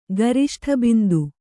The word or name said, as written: ಗರಿಷ್ಠಬಿಂದು